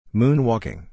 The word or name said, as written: moon-walking